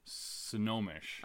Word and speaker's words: Snohomish: Snohomish. – Oh, very similar. Then. – Yeah. – Okay. – The O and the N is flippy-floppy. – So Jennifer from Snohomish messaged to say that it is indeed, well, this was a Better Know listener we did recently, mm-hmm.